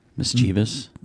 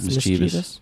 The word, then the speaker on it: mischievous: Mischievous. – What people say is mischievous. – Yeah. – Yeah. – It's not mischievous. – Oh, here's a good one. – It's mischievous.